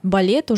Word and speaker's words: ballet: По-французски балет — это бале с двумя буквами «л». На конце пишется буква «т», которую мы не произносим.